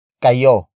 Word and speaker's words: kayo: After that, we have kayo. Kayo. Kayo. Kayo is spelled as K-A-Y-O.